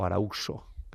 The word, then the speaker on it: Arauxo: Pues se grabó un doble CD de música de Correa de Araujo, o Arauxo, se puede escribir con X, aunque ya saben que en esta época la X se pronuncia J.